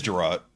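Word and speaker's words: Jerrod: With Jared Carmichael. – Oh, is that Gerard Carmichael? Is it pronounced Jared or Gerard? – It's Gerard. It's Gerard Carmichael.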